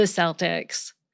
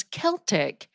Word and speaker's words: Celtic: That's spelled with a C, but I also know many academics refer to the language as Celtic, which can be spelled with a C or a K, but is pronounced like a K. So I started wondering, what's the deal? What's right? Is it Celtic or Celtic? Well, modern dictionaries say both pronunciations are acceptable.